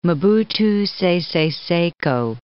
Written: Mobutu Sese Seko

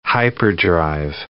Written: hyperdrive

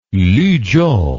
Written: luzhou

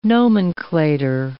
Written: nomenclator

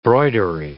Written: broidery

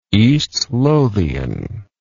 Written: eastlothian

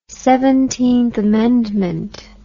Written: Seventeenth Amendment

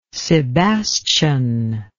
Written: sebastian

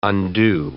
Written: undo

